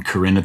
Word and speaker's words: Corinne: have assumed Corinne's name is pronounced Karen, but I was trying to protect, kind of, you know, it was the weakest disguise to protect her innocence. But now everybody calls her Karen, and that's not how you say her name. And so another part of naming that song Corinne at the Liquor Store and making it the chorus is just so people knew how to pronounce her name.